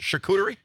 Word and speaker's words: charcuterie: Charcuterie? – Yeah.